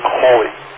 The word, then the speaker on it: Halley: The family, I was told, pronounces the name Holy.